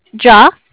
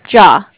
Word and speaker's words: Jia: J-I-A. – J-I-A. – Mm-hmm. – And how is it pronounced? – Ja. – Ja. Okay. I'm sorry. – I guess you can say, if you were to pronounce it, it would be like J-A-H.